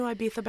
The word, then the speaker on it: Ibiza: Also, I refuse to not do the lisp. – The lisp. You have to. That's the authentic pronunciation. Ibiza. It's Ibiza.